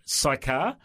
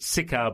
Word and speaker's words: Sychar: It's called The Silk Merchant of Sychar. I pronounced it Sychar before, but, tomato, tomato, Sychar, Sychar, whatever.